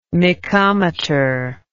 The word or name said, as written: mecometer